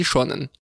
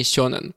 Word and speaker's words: сёнэн: Там масаси или масаши, или суси или суши, или сёнэн или шонэн. Применительно к жанру, о котором мы будем говорить, в русском языке, кажется, уже закрепилось произношение сёнэн.